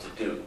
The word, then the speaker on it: Diduch: My name is Kevin DeDuke. I'm the interim principal attorney of KB Law in Haddonfield. For purposes of your record, my last name is spelled D-I-D-U-C-H and pronounced DeDuke, but please just call me Kevin.